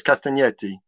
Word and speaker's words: Castagnetti: I get picked up by Italians for not pronouncing it like that, because I just say Castagnetti, but you've got the silent G, so well done. Yes, Castagnetti.